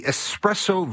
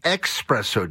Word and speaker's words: espresso: Donna weighing in with the espresso versus expresso debate. I've heard people say it wrong 3 times yesterday in the grocery store alone.